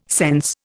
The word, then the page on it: sense: Indian